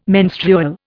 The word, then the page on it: menstrual: Indian